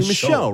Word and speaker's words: Michaud: Isn't with a D at the end? Michaud? – It looks like Michaud. It would be Michaud, right? Because the D is silent in French.